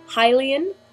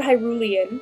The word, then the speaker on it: Hylian: But in my opinion, I have always pronounced it Hylian, like you're a Hylian person. I have heard Hyrulean, which makes a lot of sense if you're using it in that kind of context where you're saying...